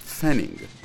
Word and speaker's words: Pfennig: For example, there's the German penny, which is called Pfennig, with an emphasis on pronouncing the letter F.